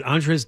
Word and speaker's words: Andrzej: President, how do you pronounce it? Andrzej Duda? – Sure, that's good. – Well, you're Polish. You're supposed to fix it. – Andrzej, is it? – Well, it's spelled A-N-D-R-Z-E-J. – I don't know. D-R-Z-E-J.